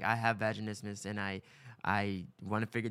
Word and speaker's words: vaginismus: Is that how you say it? – Yes. – I have vaginismus. Doesn't sound right to when it comes out of me. – You're saying it right.